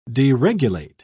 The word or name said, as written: deregulate